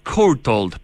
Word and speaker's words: Courtauld: ¿Courtauld? – Courtauld, perfecto.